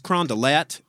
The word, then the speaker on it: Carondelet: Where is Carondelet Street? They will not know what the fuck you're talking about. They will say, it's Carondelet, and it's over there.